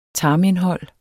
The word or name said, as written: tarmindhold